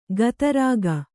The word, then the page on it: ಗತರಾಗ: gata rāga